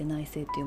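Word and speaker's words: 内省: で 4 つ目内政内政っていうのはあのうちの反省の性って書いて内政って読みますけど、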